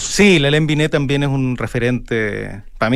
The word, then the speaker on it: Hélène Binet: También hiciste clases con Hélène Binet,